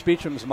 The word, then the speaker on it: Beauchamp: Well, I remember when Reese Beecham's mom corrected us. – Yes. – Because it's spelled B-E-A-U-C-H-A-M-P. – Yes. – I'm half French. I thought, that's Beauchamp. No, mom's will let you know, and I'm glad she did.